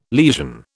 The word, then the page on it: lesion: British English